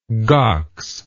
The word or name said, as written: gox